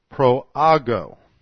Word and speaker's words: proago: The verb translated transgresses is the Greek proago.